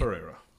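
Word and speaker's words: Pereyra: Well, it's spelled P-E-R-E-Y. – It's Pereira. – R-A. – Pereira.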